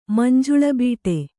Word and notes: ಮಂಜುಳಬೀಟೆ: kannada

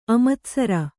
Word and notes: ಆಮಾತಿಸಾರ: āmātisāra

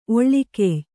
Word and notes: ಒಳಕೆಯ್: oḷakey

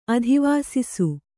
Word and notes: ಅಧಿವಾಸಿಸು: adhivāsisu

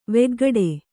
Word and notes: ವೇಗಗೂಡು: vēgagūḍu

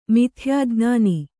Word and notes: ಮಿಥ್ಯಾಜ್ಞಾನಿ: mithyājñāni

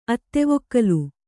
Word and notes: ಅತ್ತೆವೊಕ್ಕಲು: Kannada

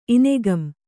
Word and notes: ಇನೆಗಂ: Kannada

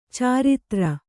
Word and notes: ಚರಿತ್ರೆ: kannada

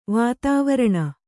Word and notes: ವಾತಾವರಣ: vātāvaraṇa

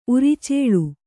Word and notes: ಉರಚಲ್ಲಿ: uracalli

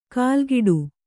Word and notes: ಕಾಲ್ಗಿಡು: kālgiḍu

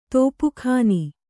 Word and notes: ತಪಕ್ಕನೆ: tapakkane

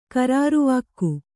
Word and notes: ಖರಾರುವಾಕ್ಕು: kharāruvākku

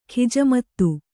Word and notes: ಖಿಜಮತ್ತು: khijamattu

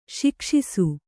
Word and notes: ಶಿಕ್ಷಿಸು: śikṣisu

